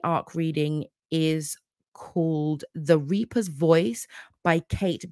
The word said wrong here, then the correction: Kat: It's B-E-T-H-A-L. Cat, not Kate. Sorry, I even said that wrong.